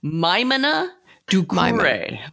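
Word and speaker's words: Maimouna Doucouré: Are you sure it's Maimouna? – It is because I finally looked her up pronouncing her own name. – Say it again. – So it's Maimouna Ducouré. – Maimona Ducouré. – And I had to memorize it because I was getting it wrong too.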